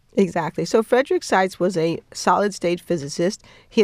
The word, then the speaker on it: Seitz: And let's explain who Seitz is. Am I pronouncing that right? – Yes. – Seitz.